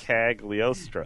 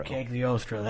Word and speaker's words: Cagliostro: Lupin the Third, the Castle of Calixtro. – Castanoli. No, it's the Castle of Castiog. God damn it. I gotta find it now. I know how to say it. It's Cagliostro. – Cagliostro, that's it.